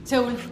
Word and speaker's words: Seúl: Seúl.